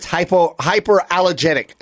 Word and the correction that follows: hypoallergenic: Allergenic. How do you say it, Kalen? – Hypoallergenic. – There you go.